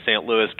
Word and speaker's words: Louis: Do you actually say St. Louis, or do you actually say St. Louis with more of the true French pronunciation of the name? – Oh no, we're all American here. We say St. Louis, King of France, Catholic Church and school.